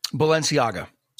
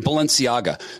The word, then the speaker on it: Balenciaga: Balenciaga. – Yeah, Balenciaga.